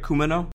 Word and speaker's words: Kumano: A few years ago, a relationship was established between the Kumano, and that, is that right, Kumano? – Yes, that's right, Kumano, yes.